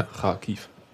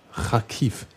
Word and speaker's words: Kharkiv: Wie wird es denn ausgesprochen jetzt, um es mal also, für die Hörer also, nachvollziehen? Nochmal auf Ukrainisch, ja, ja. – Kharkiv. Kharkiv. Mhm. So müssten die das eigentlich sagen, ja?